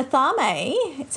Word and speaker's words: athame: So an Atharme, so it's spelled A-T-H-A-M-E for anyone that's wondering.